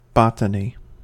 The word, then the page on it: botany: American English